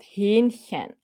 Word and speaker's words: Hähnchen: Mein Lieblingsessen ist Hähnchen. Okay. Also Hähnchen schreibt man so mit stummen H.